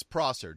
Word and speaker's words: Prosser: How do you say it? – It's Prosser, John Prosser, and he's been around in the tech world for a while.